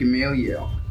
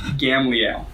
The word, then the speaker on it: Gamaliel: And because of how I just pronounced it, I do think it's pronounced Gamaliel. There is an alternate spelling and alternate pronunciation that I'm going to go with, which is Gamliel.